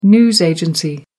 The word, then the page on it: news agency: American English